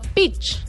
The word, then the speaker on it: Peach: Se llama Pitch. ¿Pitch? Sí. – ¿Cómo se escribe? – P-E-A-C-H. – Como Honduras Como En inglés. – pitch. Pitch, sí. Pitch.